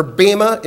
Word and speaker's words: bema: And the Greek word for judgment seat is the word bema, or bema in Greek is how you'd pronounce it, bema seat.